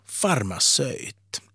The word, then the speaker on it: farmaceut: Sverigesvenskar, de kan rygga lite baklänges när de hör hur finlandssvenskar uttalar orden terapeut och farmaceut. För det heter nämligen terapeut och farmaceut. Var kommer detta ö ifrån?